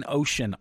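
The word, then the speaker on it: ocenaudio: The software is called, and I hope I'm saying this right, Ocean Audio, O-C-E-N Audio. I've heard it pronounced Ocean Audio in the videos and Ocean Audio. Not sure how it's pronounced, but I'm just going to call it Ocean because there's no A in it, so I don't think it's Ocean.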